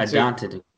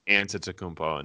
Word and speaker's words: Antetokounmpo: But I think it is Antetokounmpo and not Antetokounmpo.